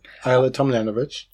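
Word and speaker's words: Ajla Tomljanović: Ayla Tomljanovic.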